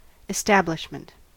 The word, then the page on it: establishment: American English